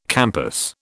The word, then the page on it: campus: British English